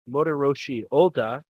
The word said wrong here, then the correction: Motoyoshi Oda: I'm pretty sure you mispronounced his name, but oh well. – Okay, how would you pronounce it? – Motoyoshi Oda.